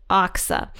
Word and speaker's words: Achsah: But I think it's pronounced Achsah. So we're going to go with Achsah.